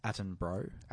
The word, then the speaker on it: Attenborough: It's pronounced Attenborough.